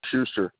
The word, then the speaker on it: Schuster: Schuster. – And how do you spell it? – It's S-C-H-U-S-T-E-R.